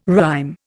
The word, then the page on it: rhyme: Indian